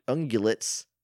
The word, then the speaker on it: ungulates: So I get there's a lot of similarities between those large horned, even toed ungulates. Ungulates? – Ungulates. I think it's a hard G.